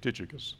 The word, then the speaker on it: Tychicus: And so Ephesus continued to figure very strongly as he chose this man named, uh, uh I listened to Alexander B. Scourby pronounce uh, Tychicus. I would say Titicus, but uh, anyway, let me just say it like he said it Tychicus.